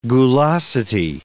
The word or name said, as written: gulosity